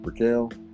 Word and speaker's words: Brickell: What how you say it, Brickell? – Brickell, yeah, downtown Miami.